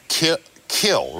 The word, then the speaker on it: kiln: Like, this is kill, right? – Kill. – But it's spelled... – K-I-L-N. – Now, and your name, too, is pronounced Favre, but it's spelled... – F-A-V-R-E.